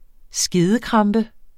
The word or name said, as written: skedekrampe